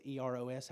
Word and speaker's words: Eros: There's, what's the E R O S? How do you pronounce that? Eros, which is selfish love.